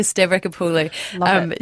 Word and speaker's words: Stavrakopoulou: Francesca Stavrakopoulou.